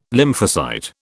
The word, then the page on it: lymphocyte: British English